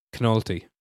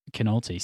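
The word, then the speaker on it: Knolte: I think that's just a pronunciation. It's like Naughty. Naughty. It's K-N-O-L-T-E. Like fair enough. – Nick Naughty, but with a K on the front. – Nice. Knolty.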